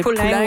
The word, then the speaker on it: Poulin: So Marie-Philippe Poulin was mispronounced a lot last night, I can confirm. Yes. – Poulin is what my friend was saying, they were saying.